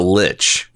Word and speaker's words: lich: How do you say that? How would you say it? I've always pronounced it lich. – Me too.